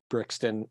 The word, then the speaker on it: Brixton: I'm always jealous because they're in places like Brixton.